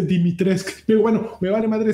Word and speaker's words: Dimitrescu: No se dice Lady Dimitrescu se dice Lady Dimitrescu. Digo, no, no, no, no, no, no mames, mija, y no me vengas a decir mamadas. Dimitrescu. Eh, no se dice Dimitrescu.